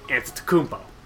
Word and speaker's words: Antetokounmpo: And that team has Giannis Antetokounmpo, who's so good that we learned how to pronounce his last name, Antetokounmpo.